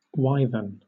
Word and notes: wyvern: British English

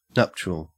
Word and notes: nuptial: Australian English

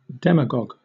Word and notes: demagogue: British English

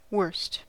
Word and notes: worst: American English

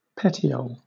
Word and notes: petiole: British English

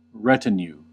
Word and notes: retinue: American English

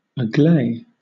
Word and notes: agley: British English

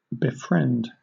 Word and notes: befriend: British English

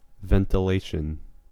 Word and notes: ventilation: American English

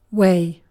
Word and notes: way: British English

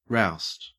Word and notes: roust: Australian English

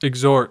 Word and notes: exhort: American English

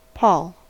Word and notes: pall: American English

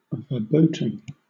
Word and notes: verboten: British English

